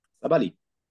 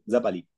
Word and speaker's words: Sabali: wie sie ordentlich ausgesprochen wird, Satu Salababi. – Sabali. Sabali. Satu Sabali.